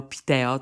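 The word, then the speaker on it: Piteå: że się tak specyficznie wymawia Huelefteo, Luleo, Meo, Piteo. To jest takie A z kółeczkiem na górze i ono właśnie tak się wymawia O.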